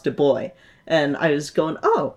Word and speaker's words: Du Bois: Not Du Bois, like I mispronounced earlier. Du Bois.